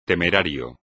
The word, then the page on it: temerario: espagnol